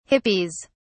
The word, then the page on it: hippies: British English